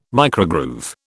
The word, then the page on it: microgroove: British English